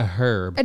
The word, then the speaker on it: herb: C, a herb. – An herb. – An herb. – Herb. Herb. – Herb. – Herb. – Herb. – Herber. The H is silent.